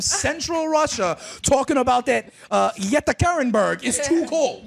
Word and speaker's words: Yekaterinburg: Yekaterinburg? – Yeah, Yekaterinburg.